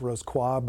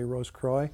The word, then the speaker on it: Rose-Croix: I won't go into too much detail on them, but my next book is really focused on the Rose-Croix degree, or more properly pronounced Rose-Croix, but most Americans say Rose-Croix. – Right, like LaCroix.